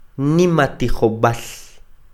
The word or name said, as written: Nimatijob'al